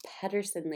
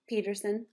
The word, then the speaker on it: Peterson: Hey guys, welcome to this episode of Social Media Secrets with me, your host, Rachel Peterson. I had to put my name in there because extra people have been pronouncing it Peterson lately, and I'm like, no, it's still Peterson.